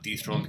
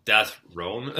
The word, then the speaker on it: Death Throne: Dig this because it's not dethroned because not, unless you want to pronounce it dethroned, but it's Death Throne, like two, two uh, one word.